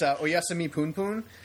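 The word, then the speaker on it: Oyasumi Punpun: Yeah, I said it wrong. It's you said it right. – No, I actually did. It's Oyasumi Poon Poon.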